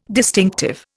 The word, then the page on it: distinctive: Indian